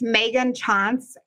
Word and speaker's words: Tschanz: It's Meghan Tschanz, and I will just spell it for you.